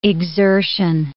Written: exertion

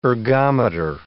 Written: ergometer

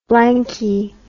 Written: blanky

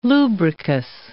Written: lubricous

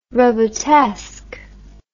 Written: robotesque

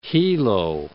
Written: Hilo

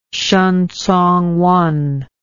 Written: shen congwen

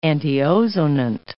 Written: antiozonant

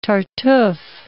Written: Tartuffe

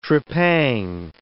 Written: trepang

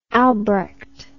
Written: albrecht

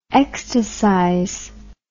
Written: ecstasize

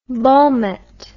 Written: volmet

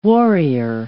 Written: warrior

